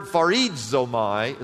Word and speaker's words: charizomai: The Greek word in this text, this particular word, is the word charizomai, is how you pronounce it.